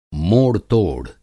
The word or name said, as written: मोड़-तोड़